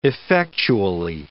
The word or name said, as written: effectually